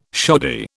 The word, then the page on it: shoddy: British English